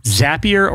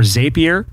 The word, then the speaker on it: Zapier: But it's a tool called Zapier or Zapier.